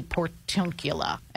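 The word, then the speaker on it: Portiuncula: What is the Portuncula? Am I pronouncing it right? – Portuncula means Portuncula. It's a sort of a diminutive dialectical form of saying little part or little portion. And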